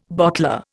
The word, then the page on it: bottler: Indian